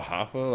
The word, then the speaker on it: Hoffa: I believe it's pronounced Hoffa. I wasn't expecting to listen to this, honestly. Someone else put me on. – It's called Hoffa, like Jimmy Hoffa.